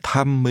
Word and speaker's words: thăm: Tôi định thăm mấy người bạn. Thăm. Thăm. – To see is pronounced with a mid-level tone.